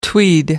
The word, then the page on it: tweed: American English